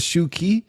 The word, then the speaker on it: Shu Qi: He opens the bag and it's fucking Shi Qi. I think it's Shi. Oh, man. I can't pronounce Chinese names to save my life. It's Shu Qi. That's the best I got.